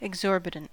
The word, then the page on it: exorbitant: American English